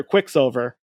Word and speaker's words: Quicksilver: And their leader, the mutant speedster, Quickster. Quickster. Wow, I said that wrong. mutant speedster, Quicksilver.